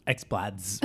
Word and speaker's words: X-Bladz: And I didn't know this until I was watching with subtitles, but it's spelled X-B-L-A-D-Z. – Yeah. – X-Blads.